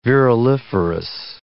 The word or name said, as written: viruliferous